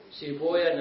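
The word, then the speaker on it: سيبويه: ولذلك كان السلف من المحدثين يكرهون النطق بويه، وإنما يقولون سيبويا نفطويا زنجويا راهويا، فخلونا على طريقتهم.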